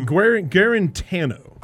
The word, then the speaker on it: Guarantano: I always call him Guantanamo. – How do you say it? – Yeah, Guarantano.